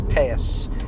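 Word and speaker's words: peyos: Well, they're called peot, but the Jewish pronunciation is payas. – Got it. Peot? – Yeah, okay. So, I don't want to get into this. But the Ashkenazic Jews, the Jews from Eastern Europe, pronounce this one letter, taf, as a tz, as an S. – Okay. – So they say peot is the word, but they say payas.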